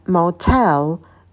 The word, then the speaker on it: motel: Мотель – это motel, motel, а в начале у нас дифтонг «л», ударение падает на второй слог.